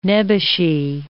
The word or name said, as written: nebbishy